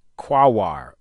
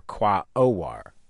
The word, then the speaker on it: Quaoar: Astronomers named it after a Native American word for the great force of creation, it's spelled Q-U-A-O-A-R, Quawar, or Quaowar. – We call it Quack because we don't really know how to pronounce it.